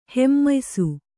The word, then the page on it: ಹಮ್ಮಯಿಸು: hammayisu